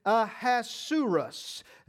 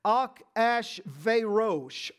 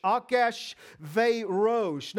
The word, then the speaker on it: Achashverosh: This is Ahasuerus, and this is the king of Persia. In the Hebrew, this is a four-syllable name, and it's actually pronounced Achashverosh. Achashverosh.